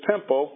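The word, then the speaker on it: Pempo (πέμπω): Okay, so we get the word A-P-O-S-T-E-L-O, long O. Okay, the Father, and I, so Jesus in the word is Pempo, P-E-M-P-O.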